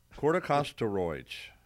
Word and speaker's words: corticosteroids: Corticosteroids.